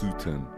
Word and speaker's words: Sutton: Is that how I say your name? Ralph Sutton. – How else would you perform? Would you pronounce it? That? Well, perform it. How else would you pronounce that? There's not too many other ways. – Sutton. – Like Sutton Place.